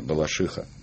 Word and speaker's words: Балашиха: Где ударение? Балашиха? – Балаших. – Балашиха. – Балаших. Только Балашиха.